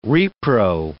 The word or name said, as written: repro